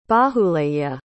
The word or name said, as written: bahuleya